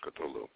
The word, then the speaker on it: Cthulhu: Now, Cthulhu was one of the, of course, there's several different ways to pronounce Cthulhu, but most everybody says Cthulhu.